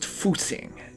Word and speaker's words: Fuxing: That's pronounced Fuxing, and it means rejuvenation, roughly.